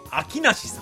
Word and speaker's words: 春夏冬: はい、で本日のお題の件ですが、春夏冬と書いて秋なしさん、名字ですね、春夏冬中と書いて秋内中さん、秋内中、すみません、これぐらいしかネタが思いつきませんでした。